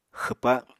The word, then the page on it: xwpa: Apatani